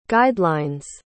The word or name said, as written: guidelines